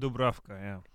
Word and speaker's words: Dubravka: Slovak. I'm going to say it the way it's supposed to be said. – I like it. At least someone could talk. – Dubravka.